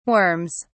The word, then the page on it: worms: British English